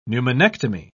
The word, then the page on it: pneumonectomy: British English